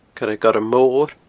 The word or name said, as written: coiregormmor